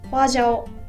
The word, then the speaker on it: 花椒: でこのマー、つまりこうしびれに欠かせない調味料が、どうでしょうね、皆さん知ってますかね、フォアジャオ、花に胡椒の椒と書いてフォアジャオ、花椒とも言いますね、をこう時々、うん、無性に食べたくなるんですよね。